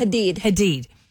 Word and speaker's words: Hadid: Hadid.